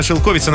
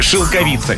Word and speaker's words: шелковица: Далее у нас была шелковица, но это неправильно. Словарь говорит, что все-таки шелковица. Непривычно, согласны, но что ж поделаешь.